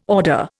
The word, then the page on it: order: Indian